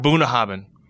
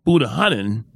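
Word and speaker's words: Bunnahabhain: Boonahabin. – Boonahabin.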